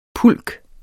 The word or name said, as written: pulk